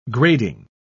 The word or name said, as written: grading